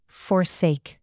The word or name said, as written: forsake